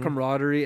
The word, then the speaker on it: camaraderie: a real team camaraderie. That's how you say it? – Mm-hmm. Camaraderie.